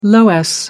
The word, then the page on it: loess: American English